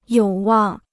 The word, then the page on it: 有望: yǒu wàng